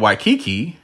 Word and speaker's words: Waikiki: And if you want to get out of Waikiki, that's how you say it, you can just drive away, or you can leave.